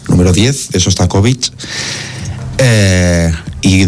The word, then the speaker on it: Shostakovich: Que es la sinfonía número 10 de Shostakovich, ¿no? Si no lo he pronunciado mal, si lo he pronunciado fatal, no es Shostakovich. – Ahí estamos. – Muy bien, ahora sí.